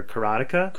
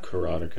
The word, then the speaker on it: Karateka: And the first one that just came out earlier this year was The Making of Karateka, or Karateka. – Karateka. It's pronounced both ways by the developers.